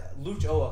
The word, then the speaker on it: Lucho: They didn't give him, I don't even know if they're being nice, they label Lucio Acosta as... It's like Lucho Acosta. I make sure I'm pronouncing the O.